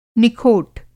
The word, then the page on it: निखोट: Hindi